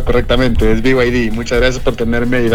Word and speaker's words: BYD: Sí, claro, es que verás, hay gente que le dice BYD, BYD, etcétera, pero el nombre y la pronunciación correcta es en inglés, es BY, que es Y en inglés, D, que es la D, entonces BYD.